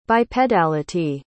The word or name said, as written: bipedality